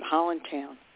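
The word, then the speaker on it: Highlandtown: called Highland Town, or as we in Baltimore pronounce it, Holland Town.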